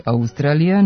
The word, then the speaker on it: Australija: Oblik Australija naveden je u matičinom rečniku sa kratkouzlaznim akcentom na drugom slogu i dužinom na sljedećem, tamo gdje se u razgovornom jeziku obično čuje dugosilazni akcenat, što je van akcenatske norme, pošto silazni akcenti mogu da stoje samo na prvom slogu u reči.